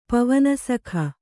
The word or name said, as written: ಪವನಸಖ